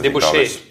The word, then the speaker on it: Debauche: und französisch, ich kann es nicht aussprechen, La Debauche. Debauche, heißt es, Debauche. ja. Nicht der, nicht der Push.